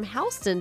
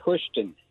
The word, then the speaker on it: Houston: But I want to go ahead and take care of calling from Houston. Did I say that right? – No, ma'am. That would be Houston.